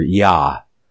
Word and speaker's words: Yah: And it's, I believe it's phonetically pronounced here, Yah. And it's spelled I-A-H instead of a Y-A-H, as in Yah. But phonetically, it says Yah, I-A-H, and rejoice before him.